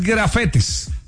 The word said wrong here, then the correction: gafete: Ah, ok. – Es gafete.